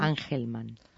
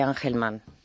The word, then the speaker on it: Angelman: El Angelman se dice. – Síndrome de Angelman. – Angelman. – Angelman. Bueno, mira, se escribe Angelman.